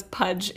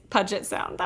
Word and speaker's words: Puget Sound: I loved Julie, before we get into Coach Taylor and Voodoo, I loved Julie pronouncing Puget Sound as Pudge. It's Puget Sound.